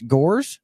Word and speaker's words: Gores: I should have asked you how to say your last name first, but I've got Marcus Gores.